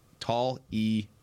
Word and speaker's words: Taliese: I'm looking at Oregon State's pronunciation guide, and it could be wrong. Bang. I've seen plenty of college pronunciation guides that are wrong. But Taliese is what they've got. Taliese.